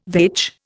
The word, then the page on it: vetch: Indian